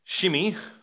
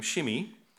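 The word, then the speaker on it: Shimei: Shimi, who Adam told us is how we pronounce his name, Shimi.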